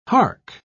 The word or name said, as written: hark